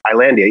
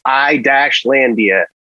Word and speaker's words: Islandia: Because in one of their early newsletters, the residents of Islandia very fiercely, made the case that, you know, they said it's pronounced I-Landia, and that's how you say it.